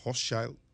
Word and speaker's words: Hochschild: Al final dos psicólogas norteamericanas que escribieron, Arlie Hochschild, o Hochschild, se, pronuncia, y Anne eh, Machung.